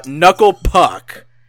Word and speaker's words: Knuckle Puck: But speaking of Jared Lange and bands, Nuclei Pook. Oh, wait, sorry. Knuckle Puck. I still have that pronunciation on the brain.